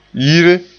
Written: yire

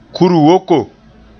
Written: kur-woko

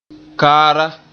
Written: kaara

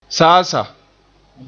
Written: saasaa

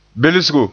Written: belsgo

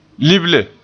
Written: lible

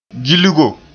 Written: gilgo

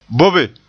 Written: bobe

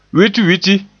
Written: witwiti

